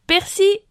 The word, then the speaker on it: persil: Les grammairiens sont divisés et les dictionnaires Larousse et Le Robert conseillent de prononcer sans le L. Persil.